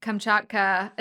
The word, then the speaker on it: Kamchatka: And the setting, which I'm sure we'll get into, Kamchatka, am I saying that correctly? – You're saying that perfectly.